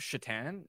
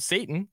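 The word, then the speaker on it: Šatan: Mine by the way, isn't that Miroslav Shatan? Isn't that how you pronounce it? – Sure. Yep. I almost called him Satan, so you're doing better than I did.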